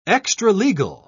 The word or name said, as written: extralegal